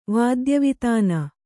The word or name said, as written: ವಾದ್ಯವಿತಾನ